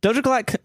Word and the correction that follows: Doja Cat: Doja Clat? Clat? – Doja Cat claims. Did I say that right? – Yeah, you did.